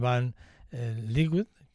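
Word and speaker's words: van Leeuwen: Eh, una, una banda neerlandesa, eh, neerlandesa, perdón, eh, de rock, en el que la funda es Robin Van Liquid, que es así como se pronuncia,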